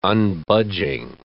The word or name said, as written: unbudging